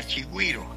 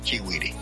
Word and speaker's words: chigüire: ha cambiado y ha variado en alguna medida la pronunciación del nombre, pero el nombre correcto es chigüire.